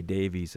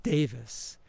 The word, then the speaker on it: Davies: In your book, you write about what seemed like a complicated relationship that you had with Ray Davies of the Kinks. – So when you read the name, people refer to the last name as Davies, Ray Davies, but it's actually pronounces it Davis.